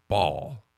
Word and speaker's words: Bhaal: Is it Bhaal or is it Bhaal? – So it's Bhaal. That's the only pronunciation I've ever seen.